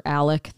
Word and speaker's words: Alex: I think the documentary, for people who want to know more about, and depending on who you're listening to, it's either Alex or Alec. They pronounce it both ways.